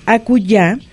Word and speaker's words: acullá: Y nada más entonces recordar que acullá es con doble L y con acento en la A. – En la última A.